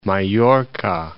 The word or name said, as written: Mallorca